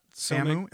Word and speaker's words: Samu: Samu. S-A-M-U.